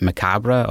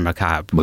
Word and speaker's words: macabre: I know, would you pronounce it macabre or macabre? – Macabre. – Macabre. Yes.